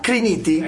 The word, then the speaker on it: Criniti: Quindi Andrea Criniti. – Eccomi. – Ecco appunto. – Hai no, anche azzeccato l'accento del cognome, bravo.